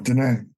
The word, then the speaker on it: Diné: I believe it's Diné people. Is that how you pronounce it? – Yeah, Diné. Yeah.